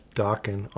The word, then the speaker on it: Dokken: This is coming from a guy who has Dokken on vinyl. – Hell yeah. Rhymes with rockin'.